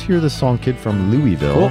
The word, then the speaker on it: Louisville: So the way you accurately pronounce that city in Kentucky is Louisville. – That's right. – Louisville. Mm-hmm. That's the correct pronunciation.